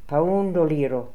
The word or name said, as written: Poundoliro